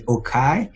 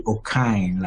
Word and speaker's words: Okai: So interestingly, here at Beeson, a lot of people have been calling me Okai because of the spelling of my name, O-K-A-I. But the pronunciation is actually Okine, like O-K-I-N-E. So I go by Okine, and Kwabena is a Ghanaian name, which simply means I was born on a Tuesday.